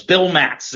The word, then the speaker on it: Bill Matz: It's two syllables, Bill Matz. It's very easy.